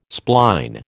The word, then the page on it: spline: American English